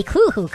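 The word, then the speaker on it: Cthulhu: A Kuhu cult, if you will. Yeah, see, I pronounce it correctly, jerks.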